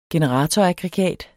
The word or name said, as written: generatoraggregat